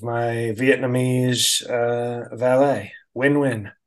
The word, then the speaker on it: Nguyen: And I said, oh, it's pronounced Win. Sorry, Win.